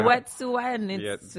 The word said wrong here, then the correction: Wet'suwet'en: Yeah, there you go. Wet'suwet'en.